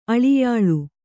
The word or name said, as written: ಅಳಿಯಾಳು